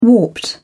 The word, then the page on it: warped: British English